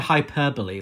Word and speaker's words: hyperbole: But she's prone to hyperbole, let's say. And just sometimes saying it's the best thing ever when it's just... – Hyperbole! – Am I pronouncing it wrong? – No, you're right.